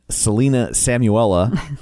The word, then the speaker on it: Selena Samuela: And then Selena Samuela, did I say that right? You did.